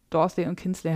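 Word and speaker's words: Dorling Kindersley: Okay, also ähm, das ist ein ähm, hellblaues äh, Buch ähm, aus dem Dorsley und Kinsley heißt es ausgesprochen, der DK-Verlag, meine ich, äh, mit so weinroter Schrift.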